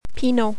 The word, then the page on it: Pinot: French